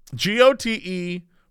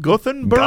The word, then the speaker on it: Göteborg: And I guess it's also G-O-T-H, Gothenburg? Gothenburg? – Gothenburg. – But according to YouTube, according to Google, the pronunciation in America is goat bog. And in British, it's Göteborg.